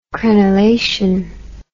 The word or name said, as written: crenelation